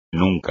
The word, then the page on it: nunca: espagnol